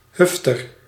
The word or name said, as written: jerk